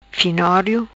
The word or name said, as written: Finorio